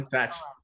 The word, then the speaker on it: Satch: Satch.